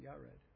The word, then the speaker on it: Iared: probably meant to be pronounced Yared.